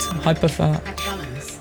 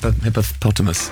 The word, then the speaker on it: Hypothalamus: How do you say that word? – Hypothalamus. – Hypothalamus. – Yeah. – I didn't like how you said it, though. – What did I say it? Hippopotamus. Hypothalamus.